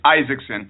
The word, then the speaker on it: Isakson: Okay, I'm here with Jim Gourley and uh, Christian Axon. Is that did I pronounce it, it right, Chris? – It, it's Isakson.